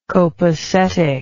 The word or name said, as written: copacetic